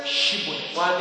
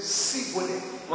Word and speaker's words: Shibboleth: Say Shibboleth. But they knew that people from that area, people from that race, people from that tribe, they cannot frame to pronounce she. They will say Sibboleth.